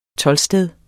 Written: toldsted

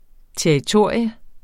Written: TERRITORIERNES

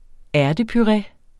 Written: ærtepuré

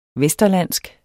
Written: vesterlandsk

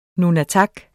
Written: nunatak

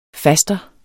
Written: faster

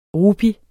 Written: rupi

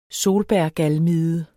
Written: solbærgalmide

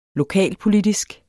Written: lokalpolitisk

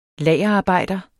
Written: lagerarbejder

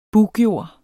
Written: buggjord

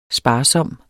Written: sparsom

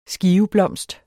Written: skiveblomst

